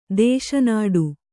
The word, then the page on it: ದೇಶನಾಡು: kannada